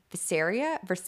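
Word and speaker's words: viscera: I looked up how to say this today. Visceria, it's internal organs.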